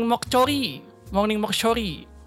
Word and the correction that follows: Moksori: apa sih bacanya Moksori